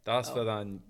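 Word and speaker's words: Daspadanya (до свидания): How do you say it? – Daspadanya. – There you go.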